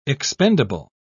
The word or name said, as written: expendable